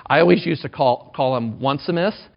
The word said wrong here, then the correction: Onesimus: It's Onesimus. O-N-E-S-I-M-U-S. Onesimus, okay?